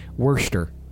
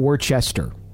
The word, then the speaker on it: Worcester: But they kind of say it more like Worcester.